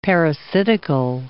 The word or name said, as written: parasitical